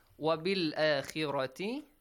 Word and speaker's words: وَبِالْآخِرَةِ: وَبِالْآخِرَةِ خِ The letter خَ is pronounced lightly here, okay? Because it has a kasra sound. وَبِالْآخِرَةِ رَاتِ. Look, heavy, light, and then heavy sound.